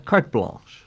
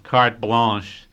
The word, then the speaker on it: carte blanche: But anyway, it was a terrific experience, and they gave me carte blanche. How do you say that? – Is it? And, carte blanche. – Yeah, carte blanche, to really go and do what I wanted to do.